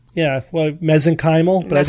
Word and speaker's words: mesenchymal: When in doubt, the accent goes on the semi-penultimate syllable, second to second to last syllable. So mesenchymal.